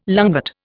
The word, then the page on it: lungwort: Indian